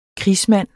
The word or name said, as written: krigsmand